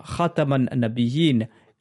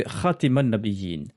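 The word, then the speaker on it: Khataman Nabiyin (خاتم النبيين): Ali lui a dit Enseigne-leur Khataman Nabiyin à la place de Khatiman Nabiyin, c'est-à-dire avec le fatra sur la lettre Ta au lieu de la kasra sous la lettre Ta.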